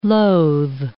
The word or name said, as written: loathe